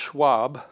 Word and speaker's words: shuwb: It's the word, I think it's pronounced Schwab, not like the people, yeah. S-H-U-W-B is the word.